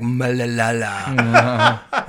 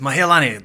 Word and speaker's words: Mahialani: It sounds like Malalala. He says, "Mahialani, that's the one."